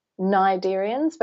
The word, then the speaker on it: Cnidarians: So corals, sea anemones, and jellyfish all belong to the same group, which are Cnidarians, but it's spelt with a silent C.